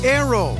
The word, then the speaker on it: Naoero: This program is previously called the country Nauru, N-A-U-R-U. The 11,000 residents have altered Nauru to be now Aero, N-A-O-E-R-O. They're going back to the pronunciation in their native language and losing a pronunciation used by outsiders.